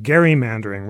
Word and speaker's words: gerrymandering: Now you can pedantically pronounce it gerrymandering rather than gerrymandering.